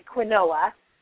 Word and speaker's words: quinoa: It's Q-U-I-N-O-A. So it looks like quinoa, but it's pronounced quinoa.